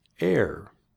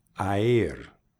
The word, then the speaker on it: air: En el principio de la palabra air hay dos vocales juntas, a y i. Pero se pronuncia esta combinación como si fuera un e. Air. Airport. No quieres pronunciar las letras por separadas, como air. No, the word is air, air.